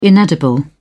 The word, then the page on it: inedible: British English